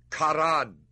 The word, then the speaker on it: Charan: The God of glory appeared unto our father Abraham when he was in Mesopotamia, before he dwelt in Charan or Haran. The CH is hard K, and sometimes in transliteration it's made an H. In the Old Testament, it is spelled H-A-R-A-N, Haran.